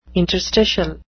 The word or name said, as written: interstitial